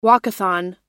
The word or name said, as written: walkathon